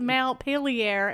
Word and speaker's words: Montpelier: Isn't it Montpelier or something? Like something Frenchy sounding, right? – It is Mount Pelier, and everybody knows that.